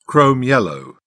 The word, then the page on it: chrome yellow: British English